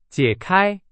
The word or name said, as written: 解开